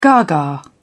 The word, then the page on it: gaga: British English